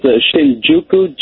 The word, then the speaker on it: Shinjuku: Is in, how do I pronounce that city in Japan? – It's Shinjuku, Japan.